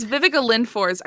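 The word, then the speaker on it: Viveca Lindfors: Yeah, it's Vivica Lindfors.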